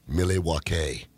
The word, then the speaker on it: Milwaukee: Milwaukee. – Is that how you say it? – That's what Alice Cooper said in Wayne's World, anyway. – Well, then that's got to be what it is.